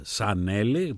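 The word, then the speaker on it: Zanelli: Ο πιανίστας ο Λάλο Σανέλη, που γράφεται με ζήτα, έτσι με σίγμα προφέρεται,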